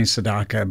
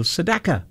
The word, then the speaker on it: Sedaka: Every time I've been saying Neil Sedaka, but he himself, I listened to him on an interview, and he said, everyone calls me Sedaka, but he says, I pronounce my name as Neil Sedaka.